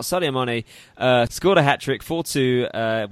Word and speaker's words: Sadio Mane: Sadio Mane, yes, that is absolutely correct.